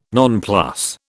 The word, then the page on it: nonplus: British English